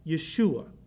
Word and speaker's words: Yeshua: In fact, the Greek name for Jesus comes from Jehovah Shua, or as the Jews pronounced it, Yehoshua, or they would shorten it to Yeshua.